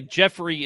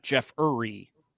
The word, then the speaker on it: Jeffrey: By the way, Jeffrey is spelled R-E-Y. My name is not Jeff Uri. So I take offense. – It's Jeff Ray. – Yes. Or as some people call me, Joffrey. – Joffrey. That's G-E-O-F.